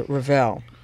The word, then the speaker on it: Revel: Well, I went to the Revel, is how they pronounce it. – Oh, is it Revel? – Yeah, for a conference.